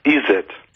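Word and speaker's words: Izzet: Nein, Izet.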